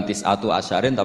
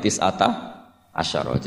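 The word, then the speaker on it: tis'ata asyara (تِسْعَةَ عَشَرَ): Tetap bacanya bukan tis'atu asyarin tapi tis'ata asyara.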